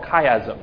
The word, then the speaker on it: chiasm: And it's called chiasm.